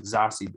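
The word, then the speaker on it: Zazie: Z-A, like she said to pronounce it as Z-A-H-S-E-E, Zossie.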